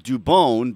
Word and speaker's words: Dubon: I mean, when I read a story about Dubon, by the way, that's how you pronounce it now, Mauricio right.